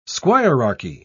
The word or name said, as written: squirearchy